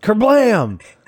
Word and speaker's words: Kerblam!: I'm sorry, Cass. It's pronounced Kerblam. – Oh, yes. There's an exclamation point. Kerblam, exclamation point. – You said it with you pronounced it with an ellipses.